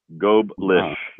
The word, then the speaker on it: Goldblish: It's like Goldblish or like Goldfish. Goldblish. – Goldblish.